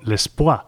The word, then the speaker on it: Lespwa: en este disco llamado L'Espoir. Se escribe L-E-S-P-W-A.